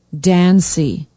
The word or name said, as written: Dancy